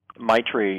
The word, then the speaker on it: Maitri: I, I might say this wrong, but I believe it's uh, Maitri. – Maitri. – Yes.